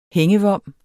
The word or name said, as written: hængevom